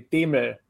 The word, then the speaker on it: Demel: It's gotten butchered, but yeah, the original pronunciation is actually Demel, which is impossible to say in English.